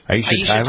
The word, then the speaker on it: Aisha: Aisha Tyler? – Uh-huh.